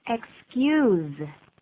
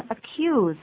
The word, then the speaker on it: excuse: Excuse. – 请再说一下。 – Excuse, 请特别注意发音哦。有的人就会念成 accuse, 啊、哦、那是另外一个意思。注意要念 excuse, 这样子念。